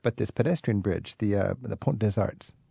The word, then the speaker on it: Pont des Arts: Le Pont des Arts. That's the Arts. – The Arts Bridge. – So you'll hear the tourists say Pont des Arts. – Yeah, you don't pronounce the T and the S.